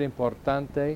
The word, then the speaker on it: importante: As they say, Lewis, I say it right, importante.